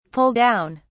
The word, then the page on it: pull down: British English